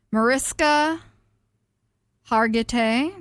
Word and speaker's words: Mariska Hargitay: Mariska Hargitay.